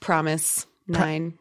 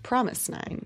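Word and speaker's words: fromis_9: Yes, which I read is actually pronounced Promise Nine. – Oh, because there is no F.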